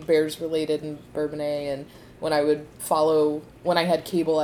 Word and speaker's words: Bourbonnais: Looks I can never pronounce it's Bourbon. Bourbons. – Bourbonnais.